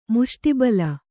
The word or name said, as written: ಮುಷ್ಟಿಬಲ